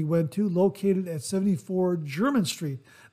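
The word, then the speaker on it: Jermyn: It's spelled J-E-R-M-A-I-N-E. I believe it's pronounced German. If I'm incorrect, forgive me, but I think I heard it pronounced German somewhere online.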